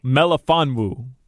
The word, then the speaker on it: Melifonwu: I don't know if I'm saying his name right. – You're not. – Like, we'll see if he can replace – I'm not. Belafondo, what's his name? – No, Melifonwu.